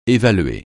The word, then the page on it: évaluer: français